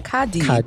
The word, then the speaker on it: KARDI: Their song, no, just Cardi, K-A-R-D-I, and their song is Watch Out. So, Cardi, if I'm saying that right, how do you pronounce this? – Cardi. R is silent.